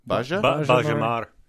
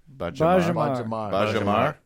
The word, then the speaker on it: Baia Mare: B-A-I-A. – Okay. – M-A-R-E. So possibly Baia-Mare. – Baia-Mare. – Baia-Mare? – Baja? – Baja-Mare. – Baja-Mare. – There we go.